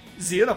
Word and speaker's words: Xena: Zina, a pronúncia correta é Zina. X é com o início de frase, em inglês é Z.